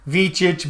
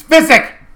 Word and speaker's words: Visick: I go, well, with the last name like Visick, I get a pass on getting names wrong because people mispronounce my name wrong for ever since I was born. Vichich, Vikich, Vichichi, Visich. It's Visick. Visick, I tell you.